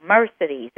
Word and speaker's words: Mercedes: And one of the helps that I give is imagine as you're beginning to say my name that someone hits you on the back, and so it comes out, Mercedes, that accent on the first syllable.